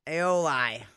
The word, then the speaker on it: aioli: How do you say it? – Aioli. Like Paoli.